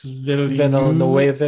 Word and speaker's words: Villeneuve: From Legendary Pictures, it's by, I'm going to butcher his name because I can't pronounce the director's name. It's like Denis Villeneuve.